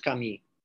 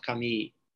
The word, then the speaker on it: Camus: I very rarely hear people in Poland say Albert Camus. Camus. Yeah. They usually say Albert Camus. Sure. Because he doesn't have this way of pronouncing, which would be in any way similar or um, easily uh, grasped by Polish uh, to, to, to, to identify uh, with a French pronunciation.